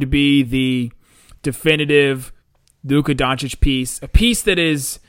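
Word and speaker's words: Dončić: Not really a joke, but she was talking in the middle about how she was talking to Doncic, and she did clarify it is Doncic, and which is hilarious because Woj pronounced it wrong the entire time.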